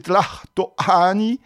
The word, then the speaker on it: Tlatoani: Il faudrait même prononcer Tlatoani, nom qui veut dire celui qui parle, l'orateur.